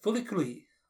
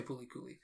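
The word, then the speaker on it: Furi Kuri: Yes, my number six top anime is, and I'll probably butcher the pronunciation, but Furikuri, or sometimes called Furikuri, like a R sound instead of an L sound.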